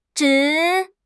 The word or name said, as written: zhi3